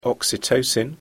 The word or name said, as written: oxytocin